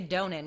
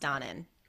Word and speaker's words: Donen: So Stanley Donen, who some people say Donen because that's how it's spelled, but I think it's pronounced Donen. That's what I've always heard.